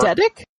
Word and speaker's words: eidetic: Perfect spy that he is, I'm sure has an edict memory. – So, he remembers everything about Yor. – Eidetic? – Thank you, yes. I knew I said it wrong as soon as I said it.